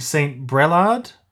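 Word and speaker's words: St. Brelade: My mum said they lived somewhere, I think St. Brelard. Is that how you pronounce it? – That's right. You've got very good pronunciation too.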